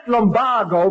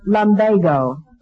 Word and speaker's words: lumbago: Mr. Schultz, that word is pronounced lumbago. – Oh, they changed it recently? – They never changed it. It's always been pronounced lumbago, according to Webster.